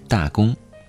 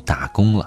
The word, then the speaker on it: 大工: 先是调侃了一下学校自己的简称，大连理工的简称就是“ 大工”，他希望大伙儿不要读成“ 打工” 了。